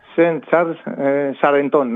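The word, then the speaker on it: Saint-Charles Charenton: Η οποία είναι να πω ο Στεφάνη Λαρόζα, είναι, δεν ξέρω γαλλικά, διαβάζω, Σεντ Τσάρλ Σαρεντόν, αν την προφέρω σωστά. – Ναι, Σεντ Τσάρλ Σαρεντόν, ναι.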